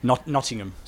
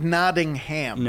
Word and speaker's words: Nottingham: How do you say it properly? – Nottingham. – So it's not Nottingham.